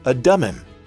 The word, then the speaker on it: Adummim: For instance, one of the names you heard today was spelled A-D-U-M-M-I-M. Do you say that Adumim, Adumim, Adamim? The correct pronunciation is Adumim.